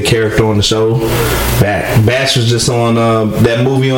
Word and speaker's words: Bach: I don't know how you pronounce it. Batch, B-A-C-H, whatever that dude. – He's Batch.